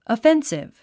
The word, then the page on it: offensive: əfénsiv; アフェンシブ